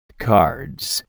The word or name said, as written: cards